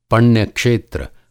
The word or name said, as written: पण्य क्षेत्र